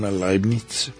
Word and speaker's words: Leibniz: Leibniz, siglo XVII. – Que lo pronuncias muy bien, porque yo viví en la calle de Leibniz.